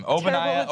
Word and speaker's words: Ogbonnia: Ogbonnia Okoronkwo.